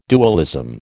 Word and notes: dualism: American English

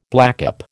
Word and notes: blackcap: American English

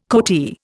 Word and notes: coatee: Indian

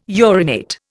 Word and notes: urinate: Indian

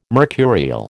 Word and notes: mercurial: American English

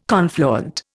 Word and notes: confluent: Indian

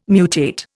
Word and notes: mutate: Indian

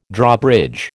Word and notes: Drawbridge: American English

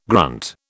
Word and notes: grunt: British English

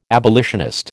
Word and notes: abolitionist: American English

